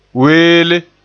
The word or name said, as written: weele